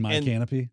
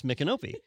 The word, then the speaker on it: Micanopy: And the GPS will say my canopy every time. – Yep. Just the, as you were saying proper nouns, I'm like, yeah, my canopy. That's why I mispronounced it like the first 3 years I was here because of my GPS.